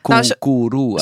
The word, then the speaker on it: koekeroe: Dus K-O-E. Koekeroe. – Zo spreek ik het dus wel.